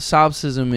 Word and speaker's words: solipsism: Solipsism is like a view that you're the only real person in the universe. – By the way, your pronunciation on that, beautiful.